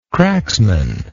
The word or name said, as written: cracksman